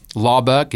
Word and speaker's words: Laubach: Rhett is R-H-E-T-T. Laubach is L-A-U-B-A-C-H.